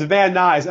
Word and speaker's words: Van Nuys: How do you pronounce the name of the city? Van Nuys? Van Nuys? – It's Van Nuys.